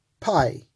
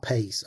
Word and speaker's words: pais: Paidion, P-A-I-D-I-O-N, paidion, which means a young child from about the age of 2 to 12. This word originates from the word pai, not P-I-E, P-A-I-S, if I'm pronouncing it right. It's not pace, I'm pretty sure of that. Pai, P-A-I-S.